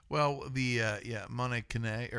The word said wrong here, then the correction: Mouton Cadet: Mouton Cadet.